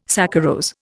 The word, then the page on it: saccharose: Indian